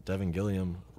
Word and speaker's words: Devin Gilliam: We got Devin Gilliam, Rare.Img.